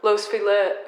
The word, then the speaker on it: Los Feliz: Yeah, there's one of my friends has an office down the street on Los Feliz in Vermont. – I just call it Los Feliz. But then there's there's people that correct me and say it's Los Feliz.